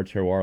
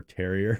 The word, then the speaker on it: Terroir: Bar Terroir. A lot of people pronounce it Bar Terrier, which I'm totally fine with. Yeah, it's spelled T-E-R-R.